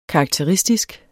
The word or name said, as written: karakteristisk